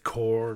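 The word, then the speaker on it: Korps: I do know it is Death Corps of Krieg. Like, it is pronounced without the P, but like, yeah, it's just, we just slip up.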